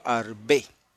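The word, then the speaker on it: arbé (ארבה): Esta langosta, que es el insecto que se puede comer porque es limpia, se llama en hebreo arbé. La langosta de mar se llama en hebreo sartán yam. Sartán yam. ¿Ok? Gracias, Migdal. Así se escribe, sí. Aleph, Resh, Bet, He.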